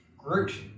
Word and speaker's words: Gruit: Groot, pronounced correctly this time.